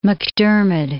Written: MacDiarmid

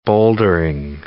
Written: bouldering

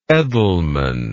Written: Edelman

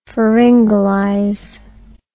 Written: pharyngalize

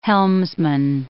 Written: helmsman

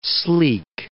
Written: sleek

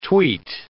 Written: tweet

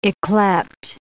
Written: ycleped

